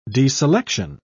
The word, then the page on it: deselection: British English